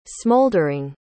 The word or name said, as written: smoldering